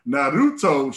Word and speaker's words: Naruto: I got to say it right. Not Naruto. Naruto shirt.